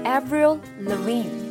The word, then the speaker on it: Avril Lavigne: 好，她的名字啊叫做 Avril Lavigne。